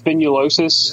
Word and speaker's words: spinulosis: I noticed that heavily as well with the I'm so bad at Latin. If I haven't heard someone else say it, I always say it wrong. But it's spinulosis. – That sounds right to me.